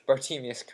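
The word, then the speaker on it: Bartemius: Bartimius Crouch did not Bartimus. – I pronounced it wrong initially. Bartimius Crouch. – Bartimus. – There's an I.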